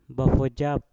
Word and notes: bafojap: Mpur

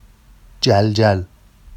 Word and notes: ʤalʤal: Vafsi

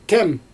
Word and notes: tɛm: Xyzyl

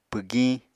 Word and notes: pwgiñ: Apatani

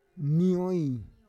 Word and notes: nioi: Roon

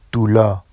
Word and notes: dulɑ: Vafsi

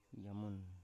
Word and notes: yamun: Biak

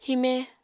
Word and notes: hime: Apatani

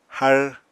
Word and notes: har: apatani

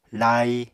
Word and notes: lai: Apatani

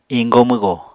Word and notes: iñgo-mwgo: apatani

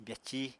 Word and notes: byací: apatani

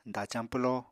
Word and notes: dacañ pwloo: apatani

